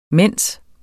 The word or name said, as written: mens